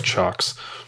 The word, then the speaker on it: Ciocks: So it's spelled C-I-O-C-K-S, but it's pronounced Chox. It looks like clocks in their logo, but it's Chox.